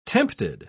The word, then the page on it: tempted: British English